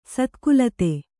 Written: ಸತ್ಕುಲತೆ

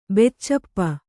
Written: ಬೆಚ್ಚಪ್ಪ

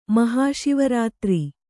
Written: ಮಹಾಶಿವರಾತ್ರಿ

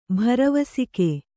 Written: ಭರವಸಿಕೆ

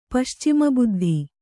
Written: ಪಶ್ಚಿಮಬುದ್ಧಿ

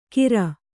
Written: ಕೀರ್

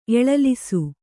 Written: ಎಳಲಿಸು

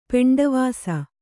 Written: ಪೆಂಡವಾಸ

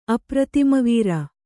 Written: ಅಪ್ರತಿಮವೀರ

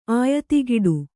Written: ಆಯತಿಗಿಡು

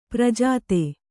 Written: ಪ್ರಜಾತೆ

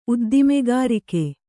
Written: ಉದ್ದಿಮೆಗಾರಿಕೆ

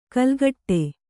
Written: ಕೆಲಕಡೆ

